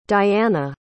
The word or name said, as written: diana